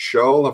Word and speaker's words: Schall: It's been drawn by George Scholl. Have I said that right? – You have.